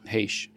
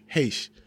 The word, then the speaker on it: Heche: Well, how do you pronounce her last name? – Heche. – Heche.